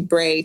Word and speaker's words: Bre: Because I guess it really shouldn't be Bree. It should be Bray, technically.